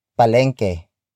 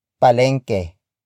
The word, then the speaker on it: palengke: And that is palengke. Palengke. Palengke. Palengke is spelled as P-A-L-E-N-G-K-E.